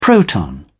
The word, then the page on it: proton: British English